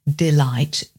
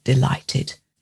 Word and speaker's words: delight: Notice the silent G-H too in that word, delighted.